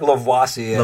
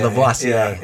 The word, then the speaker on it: Lavoisier: No, I would say like Lavoisier. – Lavoisier.